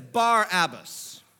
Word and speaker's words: Barabbas: His name is Barabbas, but you would say it like this, Barabbas. It's a two-part name.